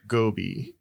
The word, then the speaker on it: Gobi: So, first of all, it's generally pronounced Gobi.